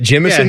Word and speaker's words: Jemison: I think how do you say that? Jemison? Yeah. Like Jimothy? – Yeah, he was in Ocean's 11.